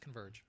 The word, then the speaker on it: Converge: And then it would be, they would try to just say it like CNVRG. And they would like try to come up with something. – Right. – I'm like, no, it's just Converge, right?